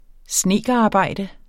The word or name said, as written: snedkerarbejde